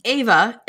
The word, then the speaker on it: Eva: The Book of Lost Names is the tale of It's written in the book as Eva, pronounced Ava in the audiobook.